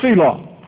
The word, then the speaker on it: tselaw: The word in the Hebrew is selaw, and it's spelled in the English T-S-E-L-A-W.